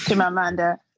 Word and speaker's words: Chimamanda: That's by Chimamanda Ngozi Adichie. I'm sorry if I did not pronounce the name correctly. – Chimamanda.